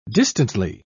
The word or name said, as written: distantly